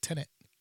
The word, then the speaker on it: Tenet: I don't even know that's how you pronounce it too, right? Tenet. – It is. Good job.